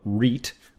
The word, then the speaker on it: REIT: But your new fund is a real estate investment trust, or REIT, which you pronounced as a REIT. That's how it's pronounced.